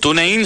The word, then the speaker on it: TuneIn: No sé cómo pronunciar TuneIn, pero digo TuneIn para que lo encuentren más fácil. – TuneIn suena muy bien.